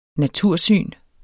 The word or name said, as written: natursyn